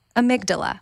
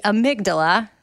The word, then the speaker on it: amygdala: Amygdala, which that's the part of the brain that's spelled A-M-Y-G-D-A-L-A. So it looks like amygdala, but it's amygdala.